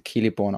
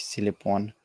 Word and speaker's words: Celeborn: Also be beziehungsweise Celeborn ausgesprochen, nicht Celeborn.